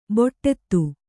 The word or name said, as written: ಬೊಟ್ಟೆತ್ತು